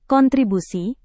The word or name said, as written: kontribusi